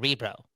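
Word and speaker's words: Örebro: Okay, the first note that I got here, again, this is Trevor's notes, brought to you by Kyle, is Lakaramäki signed to Orbro of the SHL. Am I saying that right? – I think it's a Rebro. – Oh, a Rebro.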